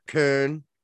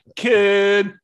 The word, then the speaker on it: Köln: We've got Köln. Is how it's pronounced in Germany.